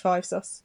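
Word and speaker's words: 5SOS: Do they call them Five Sos? – Yes, this is why now I've now changed up our pronunciation. Okay. It's officially pronounced Five Sos.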